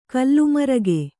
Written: ಕಾಲಮುರಿಗೆ

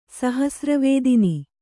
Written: ಸಹಸ್ರವೇದಿನಿ

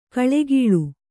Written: ಕೋಳ್ಗೊಳ್

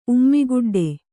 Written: ಉಮ್ಮಿಗುಡ್ಡೆ

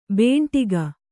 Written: ಭಾಂಡಿಕ

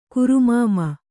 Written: ಕುರುಮಾಮ

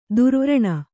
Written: ದುರ್ವ್ರಣ

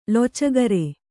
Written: ಲುಚ್ಚಾಗಿರಿ

